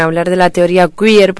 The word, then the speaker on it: queer: ¿Tú crees que esa teoría queer se pronuncia así? – Sí.